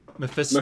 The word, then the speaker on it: Mephisto: How's it pronounced? – Mephisto. – Mephisto. – Like fist.